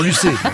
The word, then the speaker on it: Lucet: Bien sûr que je sais pourquoi nous recevons Madame Élise Lucet. – Non, Lucet. – En français, Nagui, on prononce toutes les lettres.